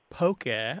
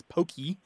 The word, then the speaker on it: poke: And now the Hawaiian word we're talking about here, to go back to the fish dish, is poke, pronounced basically as poke, although in the mouths of Anglophones, it usually comes out as pokey or poke. – Okay. – I have seen it have an accent on the E, but that is incorrect. I think people are being influenced by Pokemon, but poke.